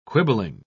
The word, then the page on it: quibbling: British English